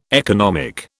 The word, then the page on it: economic: British English